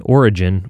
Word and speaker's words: orogen: Geologically speaking, an orogen, which is spelled O-R-O-G-E-N rather than origin, like the beginning of something, is an event or period of time when mountains formed.